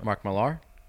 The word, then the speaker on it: Mark Millar: Oh, he's doing the other Mark Miller comic. He's doing another Mark Miller comic. – Mark Millar? – Is that how you say his name? – Mm-hmm. – Fuck, Mark Millar.